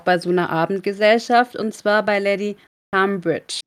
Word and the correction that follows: Trowbridge: Ja, ich würde es Thrawbridge aussprechen. – Thrawbridge? Ja. Okay, Thrawbridge. – Ich hätte es auch Lady Trawbridge gesagt. – Ja, können wir gerne Lady Trawbridge richtig aussprechen.